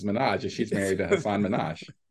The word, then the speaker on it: Minhaj: And then and then someone goes, Minhaj? And she's like, oh, that's how you say it? And they go, yeah, Minhaj, Hasan Minhaj.